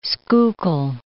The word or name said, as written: Schuylkill